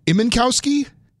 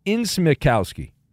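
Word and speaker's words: Incmikoski: Is it Imenkowski? – It's Insmikowski. – Insmikowski. – Yeah. – Okay. – Insmikoski. Insmikowski.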